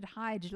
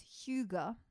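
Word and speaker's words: hygge: I thought you pronounced it Hyge, like hygiene, Hyge. But no, it's Huga.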